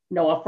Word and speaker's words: KNOA: So here's where I'm going to tell you how NOAA is spelled, K-N-O-A, NOAA Pharma.